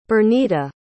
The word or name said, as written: Bernita